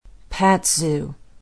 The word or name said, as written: patzau